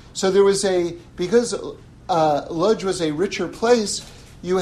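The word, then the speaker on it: Łódź: So Rabbi Meisels, Eliyahu Meisels, was a very, very great rabbi in Lodz. Lodz was kind of the richest of the Polish cities. Like, if you see it spelled in English, it's L-O-D-Z. But D-Z is pronounced J in English.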